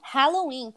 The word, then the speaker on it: Halloween: Corretamente pronunciado em inglês, como? – Halloween, pessoal, não Halloween. Saúde, Darlene. Obrigada. – Halloween, não Halloween. É com ali.